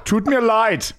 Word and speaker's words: Tut mir leid: The tone is right, but I just don't. The pronunciation is slightly off. – Mein English is under alla sus. – Mein English is under. – Sounds unter. – Like he's struggling with English. – Tut mir leid, mein Englisch ist unter aller Sau.